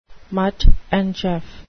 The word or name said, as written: Mutt and Jeff